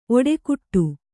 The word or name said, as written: ಒಡೆಗುಟ್ಟು